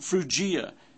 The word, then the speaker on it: Phrygia: In verse 10, it's pronounced Phrygia, Pamphylia, and Egypt, and in parts of Libya around Cyrene, and strangers of Rome, Jews, and proselytes.